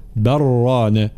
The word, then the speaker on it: بل ران: بل رانا، وهكذا. إذن اللام الساكنة تدغم في الراء المتحركة فيصيران حرفا واحدا من جنس الحرف الثاني وهو الراء، فيصيران راء مشددة، لا ننطق اللام، قل ربي قل ربي هكذا، هذا إدغام يسمى.